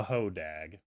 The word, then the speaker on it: Hodag: Tell us about the Hodag. – Can you spell it? – H-O-D-A-G.